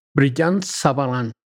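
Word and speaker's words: Brillat-Savarin: ¿Cómo, cómo se pronuncia Brillant? – Brillant Savarin.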